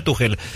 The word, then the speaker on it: Tuchel: Se pronuncia Tuchel, se escribe Tuchel, pero se pronuncia Tuchel.